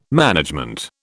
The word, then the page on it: management: British English